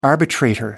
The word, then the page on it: arbitrator: American English